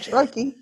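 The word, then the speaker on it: Ciarrochi: co-authored with Joseph Cherokee. – You said it right, Joseph Cherokee.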